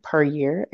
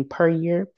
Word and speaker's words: Puryear: So on Facebook, you can find me under Tayleon Puryear, and that's spelled T-A-Y-L-E-O-N, and my last name Puryear, P-U-R. Y-E-A-R.